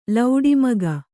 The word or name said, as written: ಲೌಡಿಮಗ